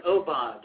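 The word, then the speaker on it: Aubade: I think it's called Al-Bad, and it was the first since, did I pronounce it correctly, or somewhat correctly? – Pretty close, Obad. Obad.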